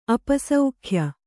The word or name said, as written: ಅಪಸೌಖ್ಯ